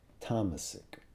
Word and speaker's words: tamasic: The gunas are all pronounced with short vowels, so it's sattva, tamas, rajas. But when they become adjectives, that first vowel is elongated, so then it's sattvic, rajasic, tamasic.